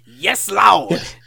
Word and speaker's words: Yes Loud: So you remember last year they released their album Yes Loved. – No, you're saying it wrong. It's Yes Loud.